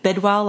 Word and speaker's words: Bidwell: It's C-A-I-T Bidwell, like I bid well on eBay. B-I-D-W-E-L-L.